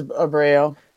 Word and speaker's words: Abreu: And that would have been Magomed versus Abreu, I think is how you pronounce his last name. – Yeah, Anaclea versus Abreu.